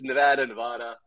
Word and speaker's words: Nevada: How much does that get to you when people kind of, because it's Nevada, gosh, I probably even said it at some time or another in this call.